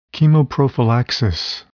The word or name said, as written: chemoprophylaxis